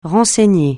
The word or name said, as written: renseigner